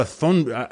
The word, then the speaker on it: Thunberg: Yeah, I think the H is silent. Is it? Uh, I'm pretty uh, sure it's Thunberg.